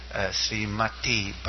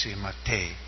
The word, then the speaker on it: Srimate: And I hear sometimes Srimati Bhaktivedanta, but Srimati is feminine, it's Srimate.